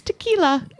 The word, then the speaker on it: tequila: I did notice, though, she knows how to pronounce tequila. – She does. Although, should that be pronounced tequila? – No. Because it's only one L.